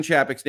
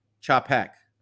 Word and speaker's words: Chapek: Okay, you are pronouncing Chappick's name wrong. It's Chappick. Chapek.